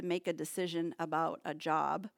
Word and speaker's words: Job: Well, don't feel bad, because job is spelled J-O-B, right? But this book is referring to a man's name, and we pronounce it Job. Job. So it's about a man, and his name is Job.